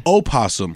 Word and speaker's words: opossum: It's pronounced opossum is there, the actual traditional spelling yeah. – and pronunciation of the animal possum.